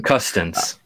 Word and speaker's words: Custance: Yeah, I listened to a little bit of the Athletics Hockey podcast today with Craig Constance. I can't say his name. – Custance. – Custance. It's, I get myself in trouble with that one. Like, there's, yeah. I always want a certain extra letter that's illegal.